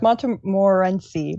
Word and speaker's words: Montmorency: Monter yeah, it's Montemorency. Montemorency. I looked it up because I was like, I don't know how to pronounce that either.